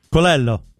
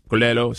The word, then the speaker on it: Colello: Colello. – Colello. So the L is pronounced in Italian.